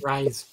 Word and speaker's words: Rhys: So, I'm going to say it wrong, but it's John Rhys Davies from Rise.